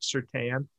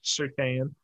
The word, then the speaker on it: Surtain: They took Patrick Surtain, and we found out that it is pronounced Surtain instead of Surtain. – Okay. – So it's Surtain.